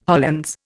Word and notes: Hollands: Indian

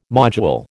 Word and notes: module: American English

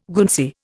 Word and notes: Guernsey: Indian

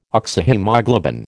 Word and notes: Oxyhaemoglobin: American English

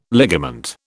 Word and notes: ligament: British English

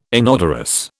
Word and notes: inodorous: British English

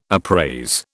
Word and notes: appraise: British English

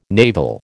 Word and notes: naval: American English

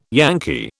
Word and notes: Yankee: British English

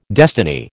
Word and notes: destiny: American English

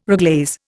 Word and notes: reglaze: Indian

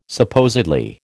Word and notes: supposedly: American English

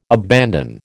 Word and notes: abandon: American English

